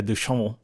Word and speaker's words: Duchamp: The French artist whose name is spelled Marcel Duchamp, but pronounced Marcel Duchamp,